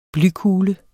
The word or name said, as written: blykugle